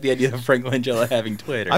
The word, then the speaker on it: Langella: I said Masters of the Universe from 1987, starring Dolph Lundgren and other important people like Courtney Cox and Frank Langella. And I'm not sure if it's a hard or a soft G. I think it's Langella.